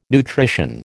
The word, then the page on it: nutrition: American English